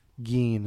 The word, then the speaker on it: Gein: Tomato, tomato, but yeah, I do believe Gein is the correct pronunciation.